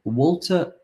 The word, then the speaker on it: Waltr: Well, it's called Walter Pro. For those of you, you might know it as Walter 2, and it's spelled Walter without the E. So it's W-A-L-T-R, and I had 2, and I now have Pro.